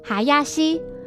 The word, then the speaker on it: 林: 英里表示那张纸条上的哈亚西指的是他跟林律师相约明天两点见面的意思，但是凶手在看到便条纸旁的客房服务的菜单后就直觉认为上面写的是牛肉烩饭。这边补充，刚才提到双木林在日语读作哈亚西，若在后面加上 lice 意思就是牛肉烩饭。